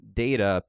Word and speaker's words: Data: Fun thing I just learned the other day, Data is named and pronounced Data because Gene Roddenberry came up with this rule where the first person to read a like fictional character, an otherworldly character's name, that they are basically dictating how it will be pronounced.